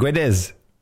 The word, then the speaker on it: Guedes: And I think it's pronounced Guedes, but the Portuguese names are pronounced differently than you'd Guedes. – Think. – Guedes.